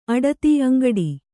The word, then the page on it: ಅಂಗಾಡಿ: kannada